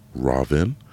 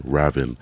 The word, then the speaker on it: Ravin: The reason I did that is because my name is pronounced Robin. So it's kind of a way to people, it's spelled R-A-V-I-N, so I would always get Raven, Raven.